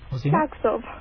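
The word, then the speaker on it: Klerksdorp: What's the correct pronunciation? – Klagsdorp.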